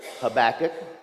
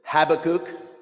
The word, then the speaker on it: Habakkuk: Habakkuk has a rather unusual name, and one that is actually pronounced different ways. There is Habakkuk, there is Habakkuk. You may even want to use halibut, or if it makes you feel comfortable, you can just say Chewbacca.